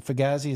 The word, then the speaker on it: fugazi: Was Week One a you know a fugazi, a fugazi.